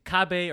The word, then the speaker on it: cabe: So the so it's no le cabe un alpista alpista, but like is cabe or cab?